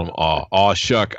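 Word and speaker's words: Shough: So I just feel like in a way it's pronounced Shuck. I always thought it was Show. – Shuck.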